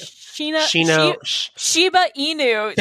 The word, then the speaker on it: Shiba Inu: They're like a Shinu Ibu. Is that how you say it? – Shiba Inu.